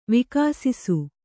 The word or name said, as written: ವಿಘಸಾಸಿ